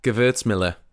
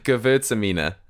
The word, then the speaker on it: Gewürztraminer: Gewürztraminer.